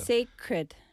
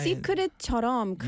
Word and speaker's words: sacred: Sacred. – Sacred. Sacred night. – Secret처럼 근데 sacred. C-R-E-D. Cred. 그거는 어렵다. 한국 사람 뭐 발음 때문에 우리 받침 때문에 받침 필요하니까 그래서 sacred, sacred.